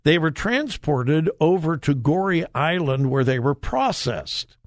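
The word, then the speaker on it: Gorée: But my understanding of slavery was changed dramatically when I visited Gori Island, just off the coast of Africa. Gori Island, I believe it's spelled G-O-R-E-E, if you want a fascinating study. Do a little internet search for Gori Island. Again, I believe it's G-O-R-E-E.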